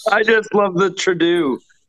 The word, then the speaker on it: Trudeau: Trudeau then. Trudeau. – was the best pronouncing of his name I've ever heard.